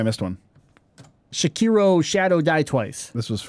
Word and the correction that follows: Sekiro: Sekiro, right? How did I say that again? Sekiro. Sekiro. Whatever.